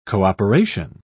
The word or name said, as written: cooperation